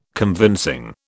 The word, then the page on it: convincing: British English